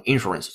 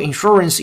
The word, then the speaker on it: insurance: 这里边有个单词啊的重音问题，很多同学经常读错，就是保险这个单词重音在第二个音节读成 insurance。很多同学可能原来习惯的读成 insurance，错了，是 insurance，insurance，insurance，哎，非常好。好的，Social Insurance Institution of Finland 就是芬兰的社会保险这个机构吧。